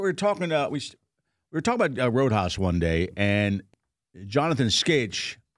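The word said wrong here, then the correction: Schaech: Sheck, God. – It's spelled weird. – I know, it's totally spelled weird.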